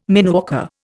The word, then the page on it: mineworker: Indian